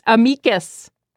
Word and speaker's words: Amicus: Dahlia is the host of Slate's Amicus, as she taught me to pronounce it, and a veteran senior writer for Slate.